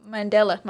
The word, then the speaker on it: Mandela: The mandala? – Mandela. My bad. The Mandela effect.